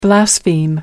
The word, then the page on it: blaspheme: American English